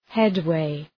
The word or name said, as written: headway